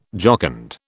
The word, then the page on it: jocund: British English